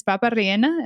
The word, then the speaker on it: papa rellena: ¿Es papa rellena? Am I saying it right? – Yeah. – Oh, those are my favorite. I'm like, yes. – So good. – All the papa rellena.